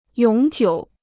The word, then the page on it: 永久: yǒng jiǔ